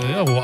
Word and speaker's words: Ferro: Und vorher noch Tiziano Ferro mit Perdono. – Wieso habe ich das richtig ausgesprochen? – Ferro, aber nee, Ferro heißt das. Hast du vollkommen korrekt ausgesprochen.